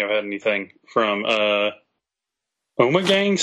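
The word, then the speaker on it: Ommegang: Omegang.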